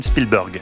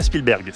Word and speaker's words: Spielberg: vous dites Steven Spielberg, et non pas Spielberg. C'est une nuance importante, on voit le polyglotte en vous.